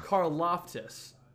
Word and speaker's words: Karlaftis: Karlaftis.